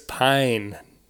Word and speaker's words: Pine: Dog, it's Chris Pine, not Prine.